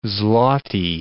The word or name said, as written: zloty